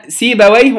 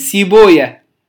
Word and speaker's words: سيبويه: وللفائدة النحويين خناقة بين النحويين وبين المحدثين، النحويين يقرؤون سيبويه أو ينطقون اسم سيبويه ونفطويه وما شابه على نفس الوزن يقرؤونها بهذه الطريقة سيبويه ونفطويه، المحدثين لا يقرؤون سيبويا نفطويا وهكذا.